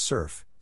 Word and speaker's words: CERF: SURF, pronounced SURF, stands for the California Education and Research Federation.